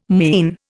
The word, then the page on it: mien: Indian